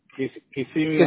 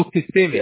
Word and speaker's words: Kissimmee: And you guys are in Kissimmee? How do you say that? – Yeah. Kissimmee in Orlando area. – Oh, Kissimmee.